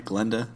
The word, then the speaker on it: Glinda: And it's Glinda, right? Am I saying that right? Glinda? – Glinda. With an I, I'm pretty sure.